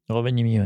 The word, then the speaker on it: Rovaniemi: Et j'ai vu que tu en avais fait une qui s'appelle, alors tu me dis si je la prononce pas mal, mais Rovaniemi. – Rovaniemi, ouais.